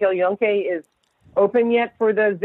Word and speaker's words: El Yunque: So, and I remember just being, I did the El Yunque, I think, is that how you pronounce it? El Yunque Rainforest? – Exactly.